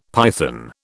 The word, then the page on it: python: British English